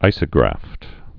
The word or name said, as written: isografts